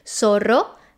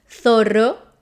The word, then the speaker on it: zorro: Z, Z in Spanish from Latin America is pronounced as an S, as in zorro, taza, feliz. And in Spain is pronounced zorro, taza, feliz.